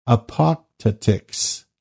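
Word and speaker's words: Apotactics: Apoptotics, that's A-P-O-T-A-C-T-I-C-S, Apoptotics, were remarkable for their condemnation of private property.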